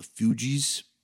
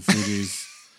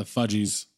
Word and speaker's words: Fugees: I would assume more Korn than the Fugees. He also would have pronounced it the Fudgies.